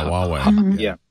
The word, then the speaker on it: Huawei: Yeah, Huawei.